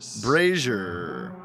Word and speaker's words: brazier: Brazier. – Rhymes with Frasier.